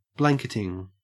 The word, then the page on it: blanketing: Australian English